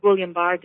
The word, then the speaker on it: Guillain-Barré: And then on top of that, I don't know if I pronounced this correctly, a French term, Guillain-Barré syndrome? – Yes, Guillain-Barré disease.